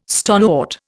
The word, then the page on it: stonewort: Indian